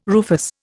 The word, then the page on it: rufous: Indian